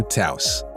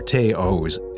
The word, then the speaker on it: Taos: It's not the Taos or Taos or Taos, and if you think it's the toes, toss, or toast, then come on, you're not even trying. It's Volkswagen's all-new, perfectly sized compact SUV, Taos. And it rhymes with house.